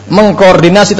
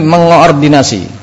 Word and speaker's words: mengoordinasi: Bukan mengkoordinasi, tapi mengoordinasi.